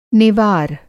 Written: नेवार

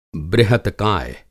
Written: बृहतकाय

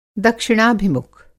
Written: दक्षिणाभिमुख

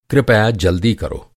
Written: कृपया

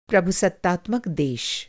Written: प्रभुसत्तात्मक

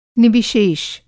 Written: निविशेष

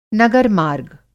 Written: नगर-मार्ग

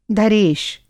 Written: धरेश

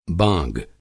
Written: बाँग

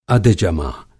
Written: अधजमा

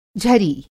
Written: झरी

